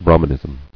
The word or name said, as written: Brahmanist